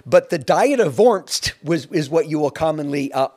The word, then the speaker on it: Worms: And in the year 1521, in the German city of Worms, it's spelled Worms, but that's not how it's pronounced. – Worms, Germany.